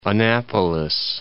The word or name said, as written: Annapolis